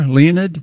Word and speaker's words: Leonid: This band called Leonid and Friends. – Yeah, I'd never heard of them until you brought them up. – Is it like the meteor shower, Leonid? – Yeah, mm-hmm. It is. Okay. That's probably the way to pronounce it, too. L-E-O-N-I-D.